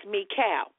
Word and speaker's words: Michal: It's pronounced Michal with a soft K. You're right. It doesn't sound like you're clearing your throat.